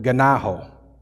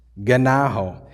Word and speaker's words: gennao: Actually, that word born, which means it's also translated begat, begot, or fathered, that is the Greek word ganaho, ganaho, which is spelled G, if you're interested in these things, G-E-N-N-A-O.